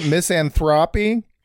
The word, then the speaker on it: misanthropy: And it's nice to take a little break from, you know, the, you know, overwhelming misanthropy of my life. Is that how you say it? Misanthropy? – Yep, I think that's right.